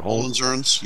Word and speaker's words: Hohenzollern: Hohenzollern? Is that how you pronounce it? – Hohenzollerns? Yeah.